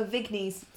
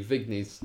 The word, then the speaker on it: Vignes: Henry Vagne. I am not 100% sure how that's pronounced. V-I-G-N-E-S. – Oh, Vignes. – Thank you, Vignes.